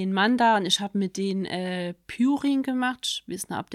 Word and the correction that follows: Pouring: Ich, den Ausdruck kenne ich als Pouring und das ist was ausschütten.